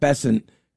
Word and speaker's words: Bessent: But Scott Bessent, and that's how you say his name, Scott Bessent has a secret plan to crush the speculators.